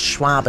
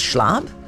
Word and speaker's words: Schwab: Like for probably about 12 minutes, people knew that I mispronounced Schwab as Schlab.